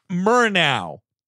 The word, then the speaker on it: Murnau: Yeah, yeah, it's pronounced like Murtaugh. – I mean, was that because it was Long Island? Like, what? It's just tell, stop the recording and be like, Jared, it's Murnau.